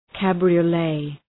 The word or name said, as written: cabriolet